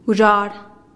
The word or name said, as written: guzhar